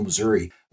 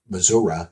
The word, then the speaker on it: Missouri: And so they cover all kinds of local sports over central Missouri, or Missouri, excuse me, I should say it right.